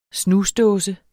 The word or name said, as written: snusdåse